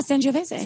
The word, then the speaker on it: Sangiovese: And I'm really in the Sangiovese right now. Is that how I say it right? – Yeah, Sangiovese. – So yeah, Sangiovese.